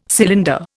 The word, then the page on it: cylinder: Indian